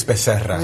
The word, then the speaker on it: Becerra: Hearing the pronunciation there, really. – It's Becerra.